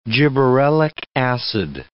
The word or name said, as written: gibberellic acid